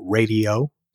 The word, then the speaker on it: Raydio: He had a band called Radio, R-A-Y-D-I-O, Radio, spelled like Ray Parker Jr. They had a big hit in 78 with Jack and Jill on their debut album, went top 10.